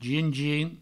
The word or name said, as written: gin